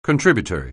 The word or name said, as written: contributory